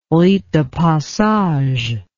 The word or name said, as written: rite de passage